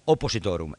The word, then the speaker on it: oppositorum: Y el andrógeno es la muestra de la coincidencia oppositorum, o pronunciado no a lo jesuítico, la coincidencia oppositorum.